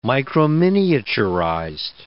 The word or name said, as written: microminiaturized